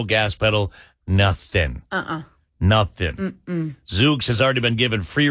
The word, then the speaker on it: Zoox: Is it Zoox? Z-O-O-X?